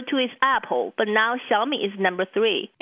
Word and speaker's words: Xiaomi: I like to pronounce it Xiaomi. – Xiaomi.